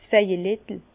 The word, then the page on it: 's Vejelettel: Bas Rhin; Herrlisheim